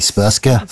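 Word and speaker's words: Srpska: I'm going to mispronounce this horribly. Spurska. – It's not far off anyway.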